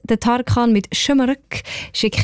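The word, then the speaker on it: Şımarık: Also man sagt «Schömerück» und das heisst übrigens so ein bisschen frech ungezogen.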